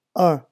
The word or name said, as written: un